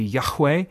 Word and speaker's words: Yahweh: There are a number of accepted pronunciations. The one that is very common, very popular, and that I tend to use is, well, I guess a little more technically it would be Yahweh.